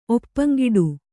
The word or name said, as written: ಒಪ್ಪಂಗಿಡು